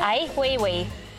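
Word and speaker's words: Ai Weiwei: Ai Wei Wei.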